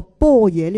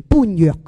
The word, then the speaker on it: 般若: 咁同埋咧，如果我哋唔系有学佛、有听经闻法嘅话，我哋亦都会将呢个波野呢两个字咧，会读成般若嘅。